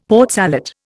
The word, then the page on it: Port Salut: Indian